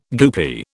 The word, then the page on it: goopy: British English